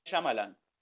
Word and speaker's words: Shyamalan: El director de esta es Shyamalan, no me acuerdo cómo se pronuncia, que es el que hizo las películas de Glass, El Protegido, esta saga de Bruce Willis. – M. Night Shyamalan.